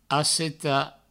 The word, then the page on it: assetar: asseta